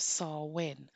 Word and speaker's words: Samhain: came from the Celtic festival of Samhain. So I'll spell that for you because it's spelt completely different to the way that it's pronounced. And it's S A M H A I N.